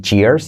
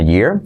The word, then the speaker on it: year: De la letra clave R, semiclave Y, para no decir cheer, sino year.